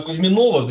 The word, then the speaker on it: Кузьминова: Давайте закончим тему все-таки э, Кузьминова, видим такое ударение, вы, да. господи, правильное ударение.